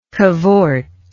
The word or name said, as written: cavorter